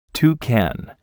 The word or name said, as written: toucan